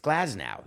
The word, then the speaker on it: Glasnow: So it's Glasgow or Glasnow? I can't be wrong with both. It's Glasnow.